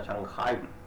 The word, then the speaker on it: Shanghai: tampoco saben pronunciar Shanghai Si no se pronuncia Shanghai, se pronuncia Shanghai.